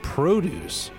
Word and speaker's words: produce: They provide free meals and produce... Sorry, I pronounced that wrong. I put the emphasis on the wrong syllable. They provide free meals and produce to anyone who is hungry.